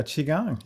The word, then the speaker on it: qigong: You are qigong, yeah.